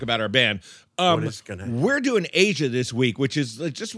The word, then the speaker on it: Aja: It's pronounced Asia, but it's spelled Asia.